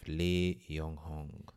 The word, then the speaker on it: Li Yonghong: of Li Yonghong. – Nice pronunciation.